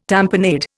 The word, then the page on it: tamponade: Indian